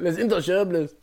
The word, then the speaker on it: Les Intouchables: Isn't it pronounced Intouchable? Les Intouchables? – Les Intouchables.